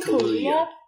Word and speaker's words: Kaluuya: I think that Daniel Kaluuya. How do you say his last name again? – Kaluuya.